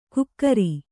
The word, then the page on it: ಗುಕ್ಕರಿ: gukkari